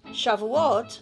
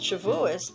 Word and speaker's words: Shavuot: Shavuot, pronounced Shavuos by Ashkenazi Jews, is not as well known, but it is one of the most important holidays on the Jewish calendar.